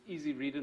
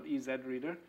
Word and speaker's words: Easy Reader: So you pronounce this the American way, otherwise the pun doesn't work. So it's Easy Reader, not EZ Reader.